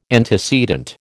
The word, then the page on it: antecedent: American English